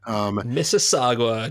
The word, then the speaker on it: Mississauga: And he's got like – he's from – I can't even pronounce this – Mississauga, Ontario. He's Canadian. – Mississauga, just west of Toronto.